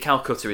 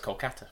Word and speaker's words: Kolkata: Is it Calcutta? Yes. He's been sent to? – No, no, it's Kolkata. – Yeah, nice fact. Calcutta is actually Kolkata, and we've been saying it wrong for ages.